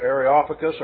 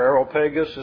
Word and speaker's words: Areopagus: Mars Hill or Areopagus or Areopagus, as some pronounce.